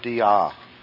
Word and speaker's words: dia: All right, let's look then at the next preposition, the word dia. Dia. And the little tick mark over the top means stress this syllable.